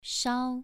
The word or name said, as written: shao2